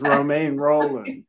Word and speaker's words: Romain Rolland: To me, it's always Romain Rolland.